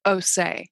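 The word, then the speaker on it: Osé: It's pronounced Osse? – Osse.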